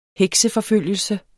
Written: hekseforfølgelse

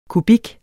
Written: kubik

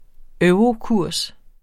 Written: eurokurs

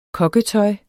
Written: kokketøj

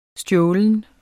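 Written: STJÅLENT

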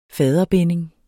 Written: faderbinding

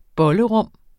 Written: bollerum

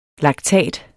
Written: laktat

